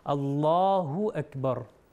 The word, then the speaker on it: اللہ اکبر: اللہ اکبر سے۔ تو اس کا صحیح تلفظ ہے اللہ اکبر، اللہ اکبر، اللہ۔ آپ کو فیل ہوگا کہ آپ جو ہیں اوپر کی طرف لے کے جا رہے ہیں، نہ کہ اللہ، جو کہ غلط ہے۔